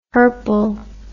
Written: hirple

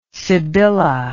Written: sibylla

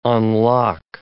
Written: unlock

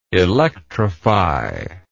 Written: electrifier

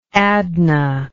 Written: adnah

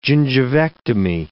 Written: gingivectomy

